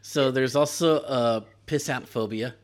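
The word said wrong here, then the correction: pistanthrophobia: No, I said that wrong. Pisanthrophobia.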